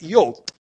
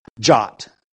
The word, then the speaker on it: yod: Jot, okay? Or yod would be the better pronunciation of that spelling.